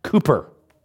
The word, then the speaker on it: Cowper: C-O-W. – C-O-W. Sorry, C-O-W, Cowper. But it's pronounced Cooper.